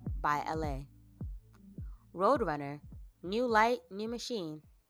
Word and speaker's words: Elhae: Like, because it's spelled E-L-H-A-E. So it's L-H-A is what it looks like. But if you say it, it's just L.A.